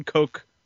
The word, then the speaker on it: Koch: They don't own Coke. Like, it's spelled K-O-C-H. – Oh, okay. – So it's actually Coke.